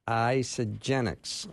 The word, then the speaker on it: Isagenix: She's the field executive for Isagenix.